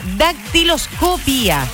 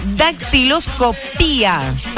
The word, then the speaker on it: dactiloscopía: Porque hoy es el Día Mundial de la Dactiloscopia. No tiene acento en la O. Tiene acento en la segunda I. Entonces es una dactiloscopía.